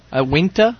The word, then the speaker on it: Uinta: So this is another barley wine, and it's made by Unita. Unita? – Okay. – Unita Brewing Company. How would you say that? Unita? – Uinta? – Uinta? – Is it, you said Uinta? – Uinta.